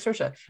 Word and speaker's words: Saoirse: It's Saoirse Ronan.